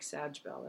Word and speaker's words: Scheibel: Although I'm on the page as Sandra McCrae Scheibel, looks like Sajbel, that's my last name, which is why I don't publish under it because no one can pronounce it.